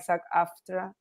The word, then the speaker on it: SAG-AFTRA: SAG-AFTRA, the other, I'm pronouncing it right, SAG-AFTRA. – Yeah. Screen Actors Guild and American Film and Television Radio Association or Agency. – Okay, is that okay? Got it. That's SAG-AFTRA. That's what I mean, SAG-AFTRA.